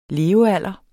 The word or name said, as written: LEVEALDRE